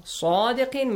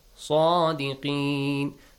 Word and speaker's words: صادقين: صادقين. Make sure it has a heavy sound. صادقين.